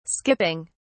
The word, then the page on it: skipping: British English